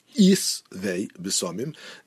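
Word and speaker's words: isvei besamim (עצי בשמים): the ayin has a chirik, isvei besamim, a shin is bishva, and it's really a sin over here, with the shva, isvei,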